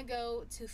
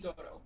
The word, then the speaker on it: Goto: Hirooki Goto. – Goto. Okay, I didn't know how to pronounce his last name, and I didn't want to be wrong.